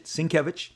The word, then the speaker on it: Sienkiewicz: But Sienkiewicz, that's a whopper. Look it up. But that's how you pronounce it, Sienkiewicz.